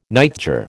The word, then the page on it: nightjar: American English